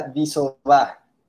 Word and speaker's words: Disoba: Mi apellido se pronuncia Disoba. D-I-S-O-B-A.